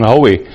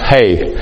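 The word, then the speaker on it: Hoeh: Now, to his credit, Dr. Hay, if you've seen his name, it looks like you'll say it's Herman Hoey. It's H-O-E-H, but it's pronounced Hay.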